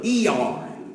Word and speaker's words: Iyar: It's the 6th of Iyar. It's spelled with an I, Y-A-R, but it's like that I's got two E's in it for the month of April, the Hebrew calendar, 5781.